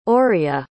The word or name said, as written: oria